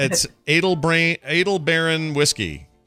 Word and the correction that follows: Aldebaran: Edelbaron? Am I saying that right? – Aldebaran. Aldebaran. – Is it Aldebaran? – Live through that pronunciation.